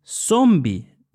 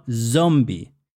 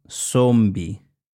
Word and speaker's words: zombi: Z-O-M-B-I, zombie. The pronunciation is zombie, not zombie. That sound z doesn't exist in Spanish. It's not used. So, zombie. Otro disfraz muy común es de fantasma, ghost. F-A-N-T-A-S-M-A, fantasma.